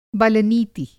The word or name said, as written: बल-नीति